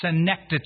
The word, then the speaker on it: synecdoche: synecdoche.